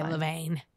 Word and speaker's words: Levain: Levin? I think it's Levin. Yeah. I think it is a Levin. – No, I was going to say Levain. It's spelled Levain.